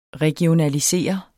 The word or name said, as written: regionalisere